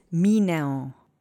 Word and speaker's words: minę: I am going to teach you ę because you do hear it slightly when this word is spoken. Let's say minę together three times. Accent is on the first syllable.